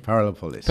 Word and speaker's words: Parallel Polis: It's parallel polis, isn't it? Parallel polis. – Parallel polis, that's right. – True. – That's correct. You just tell us, so this was my understanding. Parallel polis is a well, it's like, is it a 3 or 4 story building? – Yeah, three stories building.